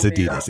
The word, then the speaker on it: Adidas: I've heard it said both ways. – No, it's Adidas. It's my Adidas.